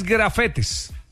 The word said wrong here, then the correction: gafete: Ah, ok. – Es gafete.